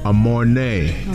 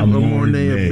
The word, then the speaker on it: Amornay: She goes by the name of Amornay. Did I say it correctly? – Yes, you did. – Know what I'm saying? Give Amornay a big round of applause for being here at 3 a.m.